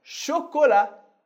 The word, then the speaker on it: chocolat: Primeiro, vamos olhar para o som fechado, o som de O, como na palavra chocolat. Chocolat. A gente tem chocolat. O T do final a gente não pronuncia. O A tem som de A. E o O, nesse caso, é sempre fechado.